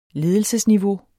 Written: ledelsesniveau

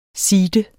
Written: seede

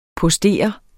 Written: postere

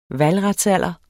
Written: valgretsalder

